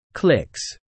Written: clicks

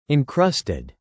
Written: encrusted

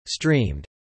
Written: streamed

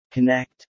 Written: connect

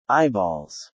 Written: eyeballs

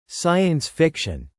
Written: Science-Fiction

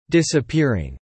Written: disappearing

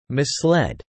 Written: misled

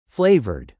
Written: flavoured

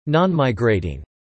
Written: nonmigrating

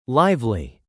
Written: lively

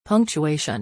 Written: punctuation

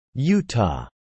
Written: Utah

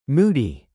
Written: moody

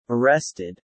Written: arrested

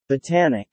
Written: botanic

